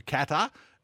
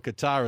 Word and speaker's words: Qatar: But we hark back to when and the decision to send this to Qatar, as they pronounce it, or Qatar as we pronounce it here in Australia, it was being very controversial.